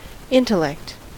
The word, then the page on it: intellect: American English